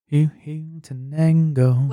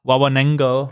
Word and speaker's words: Huehuetenango: Huehuetenango. – Huehuetenango. – What? – Huehuetenango. – Huehuetenango. – Huehuetenango.